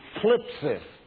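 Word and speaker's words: thlipsis: The Greek word for tribulation is one hard to pronounce. It's phlipsis. Got to watch your dentures when you pronounce that. Phlipsis.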